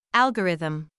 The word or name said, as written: algorithm